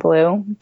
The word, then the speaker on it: Bleu: Um, it's Beige and Bleu.